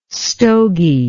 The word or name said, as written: stogy